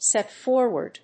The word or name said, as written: set forward